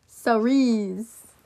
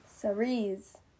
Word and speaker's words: Cerise: Cerise. – Cerise. It's French. – What is it? – It's a French word.